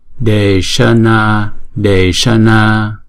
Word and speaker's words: deśana: Deśana deśana, d là hai nhịp, chữ na là hai nhịp, chữ sa là một nhịp, mà chúng ta phải ển cong cái lưỡi lên, cái lưng của cái lưỡi đó ển cong lên trên vòm trên và đọc là sa.